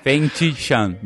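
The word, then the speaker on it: Feng Jisheng: Mas o foguete verdadeiro, realmente um foguete, foi inventado pelo chinês Feng Jishen. Eu não não sei falar pronúncia chinês então se quiser me corrigir. – feng Tishan, mas tudo bem, vamos lá.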